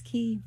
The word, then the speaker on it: Quay: How you going to do Q-U-A-Y? Is it Key? – It's Key.